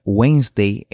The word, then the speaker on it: Wednesday: La correcta forma de pronunciar Wednesday es, bueno, Wednesday. Nunca Wednesday o Wednesday, sino Wednesday. Omitimos la D y pronunciamos Wednesday.